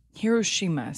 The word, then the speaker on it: Hiroshima: Hiroshima sounds correct.